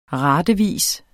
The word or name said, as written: ratevis